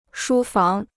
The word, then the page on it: 书房: shū fáng